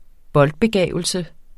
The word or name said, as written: boldbegavelse